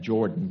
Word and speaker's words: Jordan: Now it's spelled J-O-R-D-A-N, Jordan, but he said Jordan. That was that Southern unique way of saying that.